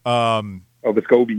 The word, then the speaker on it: Vescovi: I guess it's Vescovi. – Vescovi, yeah. – Yeah, that's what that was a big thing on the TV. Apparently, we've been pronouncing his name incorrectly the first 2 years, so we got to get used to that.